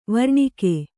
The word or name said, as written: ವರ್ಣಕ